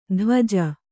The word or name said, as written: ಧ್ವಜ